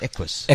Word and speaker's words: Equus: Is that how you say it? Equus. – Equus. – Equus. – Equus. It's Latin for the word horse.